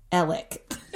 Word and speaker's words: Alex: It's spelled Alex, but it's pronounced Alec. – Great. – Because the A and the X are silent.